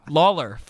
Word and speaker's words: Lawler: Oh, sorry, I mean Fergal Lawyer. Lawler. Fergal Lawyer. – Lawler. Lawler. I always screw up these names.